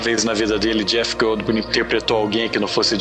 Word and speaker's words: Jeff Goldblum: Jeff Goldblum.